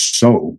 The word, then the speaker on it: seaux: And then I, in the French Foreign Legion, I found that there, there's a, a word, S E A U X, which should actually pronounced in French is so.